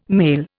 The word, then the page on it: male: Indian